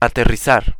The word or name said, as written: aterrizar